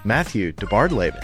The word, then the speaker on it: DeBardelaben: Matthew DeBardelaben. Did I say that right? – You did. You said it very well, actually. – It's just like it's spelled.